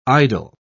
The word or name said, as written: idle